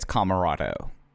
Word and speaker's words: Comoroto: I think it's Comoroto. At least Comorado. That's how they say it on. That's how they say it on commentary.